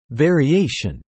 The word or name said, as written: variation